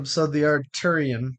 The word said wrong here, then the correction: Arteon: The Arteon. – Arteon. Arteon. I'm saying Arteon.